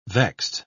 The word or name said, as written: vexed